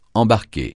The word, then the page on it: embarquer: francais